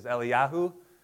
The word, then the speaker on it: Eliyahu: But remember, I taught you that if you really wanted to pronounce them correctly, Elijah is Eliyahu and Elisha is Elisha.